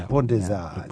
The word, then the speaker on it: Pont des Arts: Le Pont des Arts. That's the Arts. – The Arts Bridge. – So you'll hear the tourists say Pont des Arts. – Yeah, you don't pronounce the T and the S.